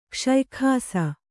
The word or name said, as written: ಕಷಾಯಾಕ್ಷ